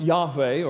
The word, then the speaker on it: Yahweh: I've told you that the name is Yod-Heh, Vav-Heh, and that it's pronounced Yahweh, or Yahweh, probably.